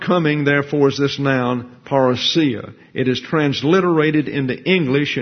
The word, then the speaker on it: parousia: It's assigned with that definition the pronunciation of parousia. And so parousia is the English translation. Parousia is the Greek.